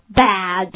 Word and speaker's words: bad: 首先第一个词是不好的词，对不对？Bad. Okay, 这是一个梅花音发音的时候往下压。 Okay, bad.